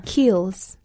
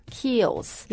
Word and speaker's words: Kiehl's: 所以这个品牌你再念一下它叫 Kiehl's. – Kiehl's. – Yes. It's like a double E.